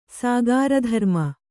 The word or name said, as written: ಸಾಗಾರಧರ್ಮ